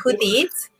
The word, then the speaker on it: Judit: So, my name is actually pronounced Judit. – Oh, I'm sorry. – That's okay. Judit Vega, and I'm a candidate for SAISD District 3 Board Elections.